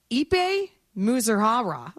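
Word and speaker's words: Ippei Mizuhara: On paper, Ipe Muzuhara.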